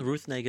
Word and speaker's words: Negga: Uh, no, um, Ruth Negga is right, and she's great.